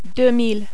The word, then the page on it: 2000: French